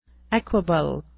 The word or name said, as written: equable